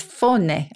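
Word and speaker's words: fáinne: And the Irish for a ring is faune, I think, F-A-I-N-N-E. My Irish pronunciation is legendary for its terribleness.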